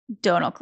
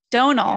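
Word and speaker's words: Domhnall: I also definitely had to YouTube look up how to pronounce Donald Gleason, because it's spelled Dom Nahal, which I had been pronouncing pretty closely to Dom Nahal. Donal, yeah. For those who didn't know, is how he says that name.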